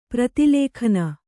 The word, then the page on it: ಪ್ರತಿಲೇಖನೆ: Kannada